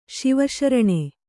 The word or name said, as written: ಶಿವಶರಣೆ